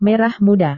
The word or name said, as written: merah muda